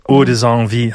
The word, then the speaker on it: Ode sans vie: Ode sans vie, habe ich es jetzt richtig ausgesprochen? – Genau, ja, weil es ist ein bisschen ein Spielwort.